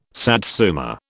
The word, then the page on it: satsuma: British English